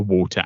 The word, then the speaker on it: Waltr: Walter Pro is the third iteration of the popular Walter app. That's spelled W-A-L-T-R.